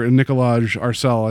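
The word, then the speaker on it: Nikolaj Arcel: I have to give credit to the director, Nicolaj Arcel. I think we've determined that's how you say his name, and I apologize because it is an odd name.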